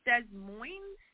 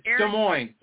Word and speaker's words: Des Moines: I believe it's pronounced Des Moines? – It's Des Moines. It's French, so the S's are silent.